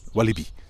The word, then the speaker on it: Walibi: En laten we heel eerlijk zijn, als je het in, op zijn Frans uitspreekt, want oorspronkelijk ligt Walibi natuurlijk in, in het Franstalige deel van België, dan is het Walibi.